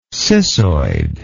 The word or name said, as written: cissoidal